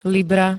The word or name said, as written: libra